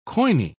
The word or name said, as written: koine